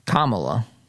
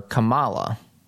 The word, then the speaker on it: Kamala: Kamala. I think I call her Kamala. It's just that I put the accent on the wrong syllable.